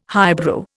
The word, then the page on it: highbrow: Indian